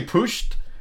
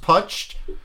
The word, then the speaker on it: Putsch: I want to say pushed because I thought it was called a push. – I did too, but then I heard it pronounced putched, and now I'm...